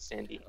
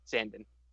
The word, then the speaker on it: Sandin: Apparently, it's Sandin, not Sandy. Oh my goodness. Yeah, the Swedes are really screwing us around with the names lately. Uh, that's how Todd Crocker pronounces it, so I'm going to go with that. – Okay. – Um, I mean, so like Sundin, but with an A.